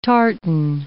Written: tartan

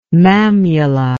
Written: mammula

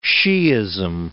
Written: Shiism

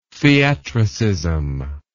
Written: theatricism

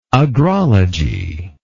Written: agrology

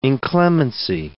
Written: inclemency

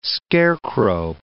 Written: scarecrow

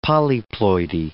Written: polyploidy

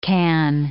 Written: can

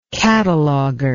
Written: cataloger

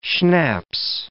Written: schnapps

